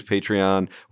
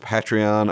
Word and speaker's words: Patreon: So we have a great post up right now, which is about how filmmakers can use Patreon, which might be pronounced Patreon. I hear some people say that, but since it's a patron that it's riffing on, I'm assuming it's Patreon.